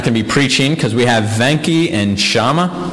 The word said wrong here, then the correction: Shami: Is that how you pronounce her name? Shami. Venki and Shami.